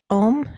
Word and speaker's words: OM: So I would love to hear about your project OM. Am I saying that right? – Yes, yes.